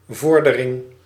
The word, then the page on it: progress: /ˈpɹəʊɡɹɛs/; /ˈprɑɡrɛs/; /prəˈɡrɛs/